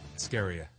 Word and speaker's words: Askarieh: A-S-K-A-R-I-E-H. – E-H. So, but that H gives it that yes. Lift at the end.